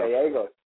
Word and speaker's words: Gallegos: So please help me welcome Paul Gallegos to the show. Paul, did I say your name right? – Yeah, you just, you don't have to pronounce the L's, it's Gallegos, but that's good enough. – Gallegos.